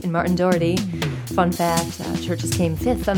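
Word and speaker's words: Chvrches: Just heard one from Churches, spelled with a V, not a U.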